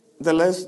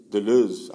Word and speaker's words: Deleuze: Deleuze no es la pronunciación correcta de su apellido es Deleuze, algo así.